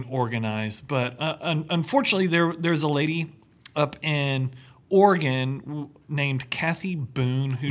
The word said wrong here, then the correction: Oregon: Let me stop you there. It's Oregon. – Oregon. – Come on. – I'm from Texas. Listen. I pronounce it however I want.